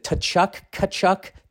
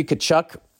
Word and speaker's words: Tkachuk: Is it Tkachuk? Matthew Tkachuk. Why is the T there?